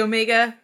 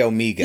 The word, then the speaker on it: Omega: Did he say Omega, or did he say Omega? – He said Omega. – Okay. Omega. That's the European way of pronouncing it.